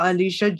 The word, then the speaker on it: Eleesha: I have a YouTube channel called Eleesha J. Sims. Then Instagram, Eleesha J. Sims. But Eleesha spelled E-L-E-E-S-H-A, because sometimes they spell it L-I-S-H.